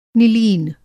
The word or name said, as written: निलीन